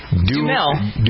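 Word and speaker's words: Duhamel: Dumel. – Dumel. – Dumel. Mm-hmm. – So there's you don't pronounce the A. It's D-U-H-M-E-L, kind of.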